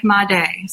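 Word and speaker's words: Madej: I tell people, remember, make Mondi.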